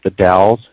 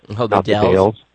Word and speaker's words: The Dalles: And just for the record, the town that they want to do this in is pronounced the Dalles, the Dales.